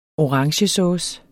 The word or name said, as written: orangesauce